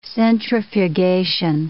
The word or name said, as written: centrifugation